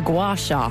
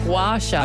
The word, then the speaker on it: gua sha: G-U-A space S-H-A. – G-U-A space. – We hope that's how you pronounce it. – A gua sha. – That's how Irish people pronounce it anyway.